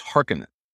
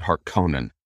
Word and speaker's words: Harkonnen: It is Harkonnen. Even though the David Lynch version 30 years ago got it so wildly wrong, they said Harkonnen,